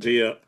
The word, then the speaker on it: Dieppe: Dieppe.